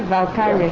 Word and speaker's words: Valkyrie: Valkyrie.